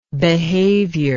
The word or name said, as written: behaviour